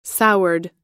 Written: soured

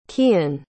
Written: kian

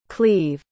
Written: cleve

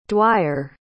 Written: Dwyer

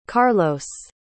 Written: carlos

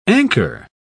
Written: anchor

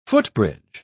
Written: footbridge

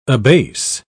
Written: abase

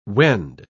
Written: wend

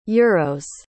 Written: euros

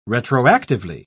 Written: retroactively

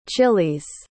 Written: chiles

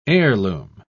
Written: heirloom

